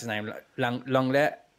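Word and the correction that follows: Lenglet: Longley. – Longley.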